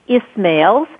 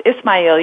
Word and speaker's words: Ismail: His name is pronounced Ismail. You're doing great.